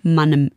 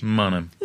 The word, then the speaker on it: Mannheim: Und ich hatte mal einen Freund, der da aus der Gegend kam und weiß deswegen, darum habe ich das eben gesagt, dass Mannheim von den Leuten, die dort wohnen, Mannem im Dialekt ausgesprochen wird. Richtig? Mannem.